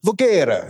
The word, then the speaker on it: Voguera: And I asked him, I was like, what's the proper way to pronounce this? And he was like, Voguera. And you got to use the hand to be properly Italian.